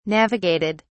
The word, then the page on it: navigated: British English